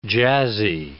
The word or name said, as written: jazzy